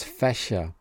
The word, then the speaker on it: Fächer: From the International Phonetic Alphabet, I've borrowed C. Cedilla. It's used for the German ich sound. So it's Fächer.